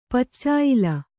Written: ಪಚ್ಚಾಯ್ಲ

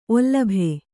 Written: ಒಲ್ಲಭೆ

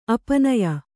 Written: ಅಪನಯ

